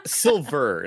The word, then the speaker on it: Silver: And just the thought that at some point we might get a Chosen versus Terry Silva fight is weird and exciting to me. – It's Silver, not Silva. – I'm saying it like you would an Italian. Okay, you know, okay. We don't pronounce bars.